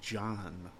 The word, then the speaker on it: JAN: J A N, pronounced John. So.